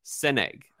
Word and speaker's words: Sanaig: And it's spelled S-A-N-A-I-G is the release. And it's pronounced Senig, I think, at least from a Scottish person that helped me out on the internet.